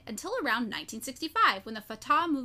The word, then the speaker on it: Fatah: F-A-T-A-H, Fatah, that's how I say it. Is that right? – Sure.